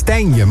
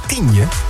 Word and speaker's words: Tijnje: Jij zegt steeds Tijnje, maar is het niet Tienje? Moet je niet Tienje? Moet je niet uitspreken als Tienje? – Mocht iemand uit Tijnje komen?